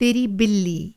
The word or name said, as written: तेरा